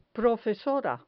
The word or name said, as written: profesora